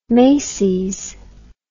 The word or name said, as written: macys